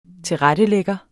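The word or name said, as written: TILRETTELÆGGER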